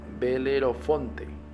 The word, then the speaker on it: Belerofonte: perdón, Acuérdense que la, pronunciación luego es medio rara. Belero Belerofonte. A ver, va de nuevo. Belerofonte. Así, Belerofonte.